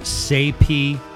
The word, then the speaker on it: Saype: You familiar with the artist Saype? It's spelled, I think, S-A-Y-P-E.